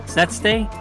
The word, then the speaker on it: Szesztay: I found one by this guy named David Zetste. think I like it because his last name kind of sounds like Zesty. But anyway, his last name is spelled S-Z-E-S-Z-T-A-Y.